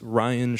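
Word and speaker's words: Rhyan: Yeah, all my stuff is Ryan Schwartz, and Ryan is spelled RHYANSCHWARTZ.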